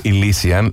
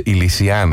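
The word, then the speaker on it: Elysian: Δεν λέγεται Ηλισιάν, λέγεται Ηλισιάν. Έτσι τονίζεται. Στη Λίγουσα.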